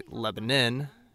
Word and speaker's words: Lebanon: Or I guess I should say Lebanon, as the folks there like to pronounce it.